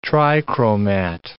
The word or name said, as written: trichromat